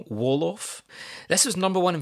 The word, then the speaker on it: Wolof: Wolof. – Wolof. W-O-L-O-F.